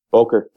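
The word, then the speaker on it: Böker: Made good connections with the guys over at Boker. Is that how you say it? Boker Knives? – Boker, yeah.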